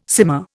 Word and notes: simmer: Indian

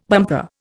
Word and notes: bumper: Indian

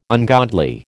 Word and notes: ungodly: American English

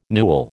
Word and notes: newel: American English

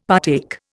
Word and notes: partake: Indian

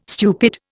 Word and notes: stupid: Indian

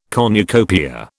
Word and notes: cornucopia: British English

